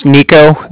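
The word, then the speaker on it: Niko: My name's Nico. That's N-I-K-O. I'm calling from the Bank of Cardiff. That's spelled C-A-R-D-I-F-F, as in Frank, Frank.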